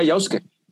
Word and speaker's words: Yasuke: Yasuke, el anime del samurái negro eh, Netflix. – O como, se, ah, pronunciaría en japonés sería Yosuke.